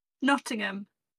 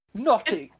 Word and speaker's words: Nottingham: Nottingham. – Okay. See, I You don't pronounce the H, really.